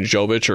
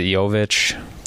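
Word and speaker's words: Jović: I saw Jimmy had a quote about, is it Jovic? – Yep. I think so. – Like the Y pronunciation of the J.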